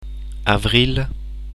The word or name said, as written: avril